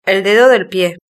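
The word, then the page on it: el dedo del pie: Spain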